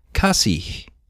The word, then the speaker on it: kasih: Notice the H at the end of kasih. Kasih and some other common Indonesian words end with an H. Even when it's at the end of the word, the H is always pronounced.